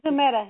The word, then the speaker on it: Zumeta: Z-U-M-E-T-A. Hello? – Yes, that's Hello? – Zamuda.